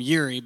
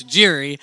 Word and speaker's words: Jiri: We've been calling him Yuri, but I learned in Mexico, or in the DR, the Y is pronounced as a J, so it's Jiri.